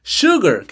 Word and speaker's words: sugar: Sugar, que es uno que se escribe con S. Sugar, azúcar. Sugar, con S.